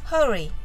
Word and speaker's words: Hurry: Hurry は実は少し難しい発音です日本語ではハリーとカタカナで書きますが英語の発音はハヒフヘホのハよりもアルファベットで書いてある通り HU のロ